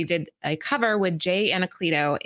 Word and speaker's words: Anacleto: I've been saying his name wrong this whole time. I thought it was Ancelito. – Anacleto.